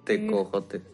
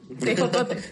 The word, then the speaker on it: tejocotes: Tejocotes. – ¿Cómo se dice? – ¿Tejocotes o tecojotes? – Tejocotes.